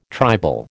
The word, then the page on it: tribal: American English